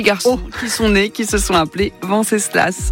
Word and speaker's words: Venceslas: Venceslas, je ne sais pas si on prononce le S à la fin. – Ouais, moi je le prononcerais volontiers, ouais. Venceslas.